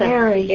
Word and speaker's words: aerie: A-E-R-I-E. – Right. – It's where the eagles live. – Right. – I don't know how to pronounce that. – Airy. – Airy.